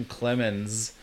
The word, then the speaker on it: Clemenz: I mean, my last name, Clemens. It's spelled C-L-E-M-E-N-Z. – I saw that. – Because the Z, it's the German.